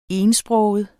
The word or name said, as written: etsproget